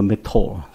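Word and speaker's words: métro: 我其实我知道他们怎么读，可是我一下子迷糊了，把它读成这个英文的读法哈，就是他们的地铁叫做 METRO，就 METRO，那个 R 的音在德文啊法文里面是要强调的那个的音，应该读作 METRO。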